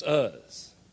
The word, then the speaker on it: Uz: And it's not Uz or Uz. It's Uz.